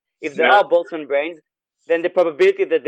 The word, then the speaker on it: Boltzmann: Like you can't avoid the X risk of what if you're a Boltzmann brain? Is that how it's pronounced? – Yeah. Boltzmann brain.